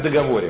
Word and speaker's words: договор: Так, что главное в договоре вообще? Вы знаете? – Ударение. – Нет. Чего? – Ударение. Ударение на второе О.